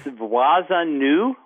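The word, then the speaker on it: Vwazen Nou: It's Wazanu, which is Creole for our neighbor, and it's spelled V as in Victor, W-A-Z-E-N, and then N-O-U. That's N-O-U,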